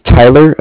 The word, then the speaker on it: Tyler: My name is Tyler. It's spelled T-Y-L-E-R, and I'm actually calling with Cardiff. It's spelled C-A-R-D-I-F-F, as in Frank Frank.